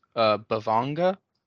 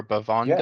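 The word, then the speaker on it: bhavaṅga: And I found something called in Pali, I believe it's pronounced Bhavanga or Bhavanga.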